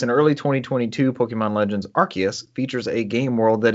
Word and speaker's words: Arceus: I was hoping for a pronunciation of that. Instead, you gave us Arceus. – Arceus.